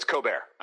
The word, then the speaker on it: Colbert: Well, there's no L, so it's Colbert.